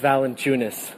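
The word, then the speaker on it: Valančiūnas: Jonas Valencianos, Valencianas. – Jonas Valencianas. Sorry. Jonas Valencianas. – Jonas, when I say Unas, when I say Unas. – So the last name is Valanciunas. – Valanciunas.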